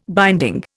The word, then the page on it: binding: Indian